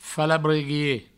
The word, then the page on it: falabreguier: Provençal; falabreguie